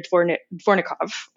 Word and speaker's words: Dvornikov: Alexander Dvornikov. I'm probably butchering that name. Um, no, that was good.